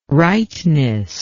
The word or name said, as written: rightness